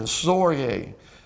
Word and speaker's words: storge: It is the word storge. Storge, and you really have to pronounce it with the H-E-E-E rather than the G, but it's spelled S-T-O-R-G-E.